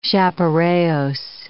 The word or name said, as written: chaparejos